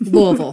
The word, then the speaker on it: Louisville: I know a lot of you are Kentucky people, but that's how you say Hey-o. – It. Louisville. – Louisville. Louisville. – Don't pay attention to how it's spelled. Don't worry about it.